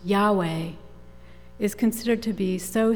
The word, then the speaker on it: Yahweh: The Hebrew pronunciation of those letters, Yahweh, is an attempt to imitate the sound of inhalation and exhalation. You breathe in Yah and breathe out Veh, but you don't make any sound other than the breath.